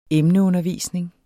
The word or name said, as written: emneundervisning